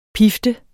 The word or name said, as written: pift